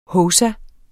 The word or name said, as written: hovsa